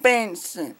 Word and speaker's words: Bensen: My name is spelled Benson. B-E-N-S-E-N. And I live in room 7. – Okay, so your last name is Benson? – Yes, ma'am. – Is that B-E-N-S-O-N? – That's E-N.